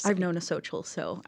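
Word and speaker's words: Xochitl: How do you pronounce her name? – Xochel? I'm assuming. – Yeah. Okay. You got it.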